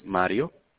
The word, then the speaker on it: Mario: Yes, my name is Mario.